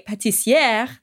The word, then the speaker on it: pâtissière: Well, yes, this week a pâtissière, that's supposed to be the feminine pronunciation, is in the news.